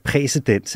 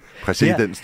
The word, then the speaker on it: præcedens: Det er jo det, man snakker om, at det danner præcedens, ikke? – Præcedens, tror jeg, det udtales. – Nej, det er præcedens.